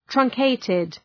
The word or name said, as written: truncated